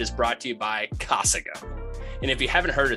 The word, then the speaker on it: Casago: Hey everyone, this episode is brought to you by Casago. And we— you mean Casago? – No, I meant Casago. – That's not how you say it.